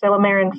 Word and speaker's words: Silymarin: I don't know how you pronounce it, Thomas, but I pronounce it Silymarin forte or Silymarin. Yeah. I don't know. – Perfect. Spot on.